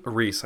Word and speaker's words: Rhys: Yeah, so the person that I thought that was voicing that that character was, I think it's pronounced, is it Rhys Darby? – Rhys, I think. – Rhys? Oh, okay. Rhys. Yeah, that makes more sense.